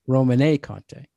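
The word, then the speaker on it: Romanée-Conti: What's the proper pronunciation? Domaine Roman? – Romanée Conte.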